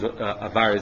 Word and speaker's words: Avar: When the Mechaber makes mention of Kelim HaMetzufim Be'ever, Ever or Avar is Avar is the correct way of pronouncing it.